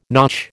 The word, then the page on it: notch: American English